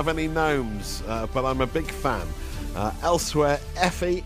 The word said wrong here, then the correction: Aoife: is it? I think that's how Aoife. – It's pronounced. Aoife in Brighton has been in touch to share a new game she's invented with her boyfriend Ryan.